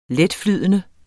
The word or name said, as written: letflydende